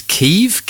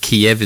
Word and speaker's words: Kyiv: No, it is Kyiv. Kyiv is the way that Ukrainians pronounce it. Kiev is the way Russians pronounce it.